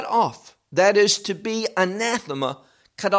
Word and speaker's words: anathema: Now, that word, the word anathema, is really a Greek word. That's how you pronounce it in Greek.